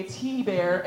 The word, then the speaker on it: T Bear: I am Teresa, aka T Bear, emphasis on the T.